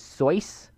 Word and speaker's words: Seuss: Side note, did you guys know the actual way uh, Seuss demanded it be pronounced was soice? It was supposed to rhyme with voice.